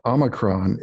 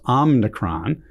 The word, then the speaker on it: Omicron: President Biden said a couple of times recently at a press conference, Omicron, which is not right. Some people are saying Omicron, but since it's a Greek transliteration, I think it's supposed to be Omicron.